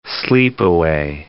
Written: sleepaway

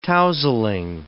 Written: tousling

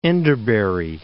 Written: Enderbury

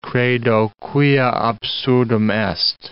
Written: credo quia absurdum est

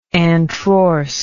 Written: antrorse

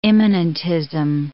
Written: immanentism

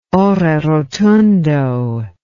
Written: ore rotundo

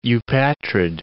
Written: eupatrid

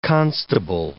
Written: constable

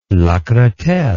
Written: Lacretelle

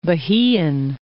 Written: Bahian

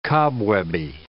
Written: cobwebby

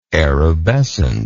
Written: erubescence

